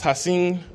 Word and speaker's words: Hashan: See, my first name is pronounced Hassin, but it's spelled Hashan,